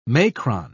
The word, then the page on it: macron: British English